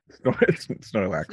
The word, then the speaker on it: Snorlax: fight Snorkelax. Did I get the name right? – Snorlax. – Snorlax, yeah.